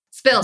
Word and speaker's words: Spilt: Spilt. Is that you? I know. I got to change. – Spilt. Sorry. I just said it wrong. Sorry. – Everybody says read. Split. So that's indicating spilt. – To me. Yeah. – SPILT.ca is your other website.